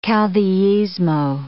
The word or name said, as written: caudillismo